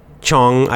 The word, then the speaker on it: Chuang Tzu: And then usually I see the spelling in with our letters of the other man, the second famous Taoist philosopher, is Chuang, C-H-U-A-N-G. Chuang, I don't know how to pronounce it in Chinese, but that's how we say in English, Chuang. And then again, Tzu, same, T-Z-U, two men.